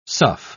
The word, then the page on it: sough: British English